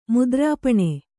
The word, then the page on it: ಮುದ್ರಾಪಣ: mudrāpaṇa